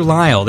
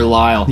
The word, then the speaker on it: Lyle: Tyler Lyle, yes, L-Y-L-E, yeah. – Tyler Lyle, there's sort of a flow to that name. – Yes, it's phonetically weird, Tyler Lyle. Yeah. If you're in the South, it's Tyler Lyle, so...